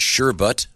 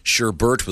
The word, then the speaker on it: sherbet: It is, in fact, pronounced sherbet. – Hmm. – It is the usual word, according to this, for the frozen sweet dessert made from fruit or fruit juices. Sherbert, with an additional R in the second syllable, is less commonly used in Britain.